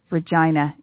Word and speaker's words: Regina: That's right, as one of the local DJs said, Regina. It rhymes with fun.